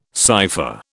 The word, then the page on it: cypher: British English